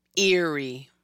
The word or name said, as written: Eerie